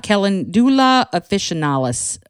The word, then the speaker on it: Calendula officinalis: It's Calendula officinalis or calendula officinalis, depending on how you pronounce it. I've heard it pronounced both ways.